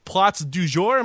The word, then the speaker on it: Plats du Jour: She is also the author of 2 books, The Girl and the Fig Cookbook and my French is awful, Plats du Jour. Am I saying that correctly? – Perfect.